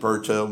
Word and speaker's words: Bertaut: You can follow me on Twitter, at Mike Berto. My last name is spelled B-E-R-T-A-U-T.